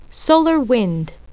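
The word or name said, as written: solar wind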